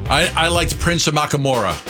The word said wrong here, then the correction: Amukamara: Amukamara. – Was that his name? Did I get it right? Prince Amukamara? – I liked Prince.